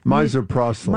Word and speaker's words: misoprostol: Misoprostol.